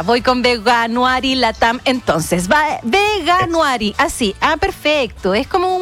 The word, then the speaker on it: Veganuary: Lo dije horrible, le voy a preguntar cómo se dice. ¿Cómo estás, Mauricio? – Bien, muy bien. ¿Y tú cómo estás? Muchas gracias por la invitación. – ¿Cómo se dice, Mauricio, por favor? Sálvame. – Mira, la verdad, ¿cómo se dice? – Veganuary.